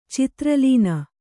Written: ಚಿತ್ರಲೀನ

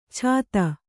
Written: ಛಾತ

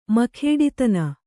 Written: ಮಖೇಡಿತನ

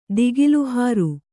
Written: ದಿಗಿಲುಹಾರು

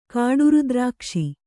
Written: ಕಾಡುರುದ್ರಾಕ್ಷಿ

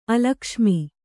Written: ಅಲಕ್ಷ್ಮಿ

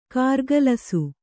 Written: ಕಾರಗಲಿಸು

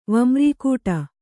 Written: ವಮ್ರೀಕೂಟ

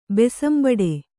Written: ಬೆಸಂಬಡೆ

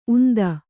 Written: ಉಂದ

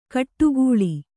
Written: ಕೊಟ್ಟೆಗೋಳಿ

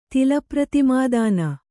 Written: ತಿಲಪ್ರತಿಮಾದಾನ

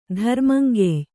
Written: ಧರ್ಮಂಗೆಯ್

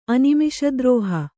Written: ಅನಿಮಿಷದ್ರೋಹ

